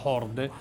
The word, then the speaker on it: HORDE: era una parola che si pronuncia horde, che in realtà è il, è il, significa Horizon of Rock Developing eh, Everywhere, cioè eh, il rock suonato in qualunque posto.